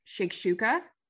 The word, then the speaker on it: shakshuka: It's called shakshuka.